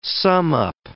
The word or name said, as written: sum-up